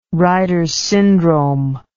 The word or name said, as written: Reiter's syndrome